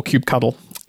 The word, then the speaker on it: kubectl: The perennial debate in the community is about the pronunciation of kubectl or kubectl.